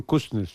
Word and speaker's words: Kuznets: El señor bielorruso Kuznets, ¿se pronuncia bien así? – Simon Kuznets, sí.